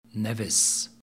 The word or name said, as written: Nevis